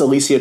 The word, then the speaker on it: Alicia: I believe that's how you pronounce her name. I think it's Alicia because that's how she introduced herself to me.